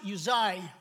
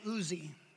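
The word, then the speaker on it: Uzzi: You know, the first guy, it's pronounced Uzai, but I like to say Uzi, okay?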